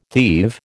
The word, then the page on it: thieve: American English